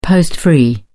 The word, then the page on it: post free: British English